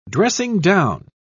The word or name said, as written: dressing-down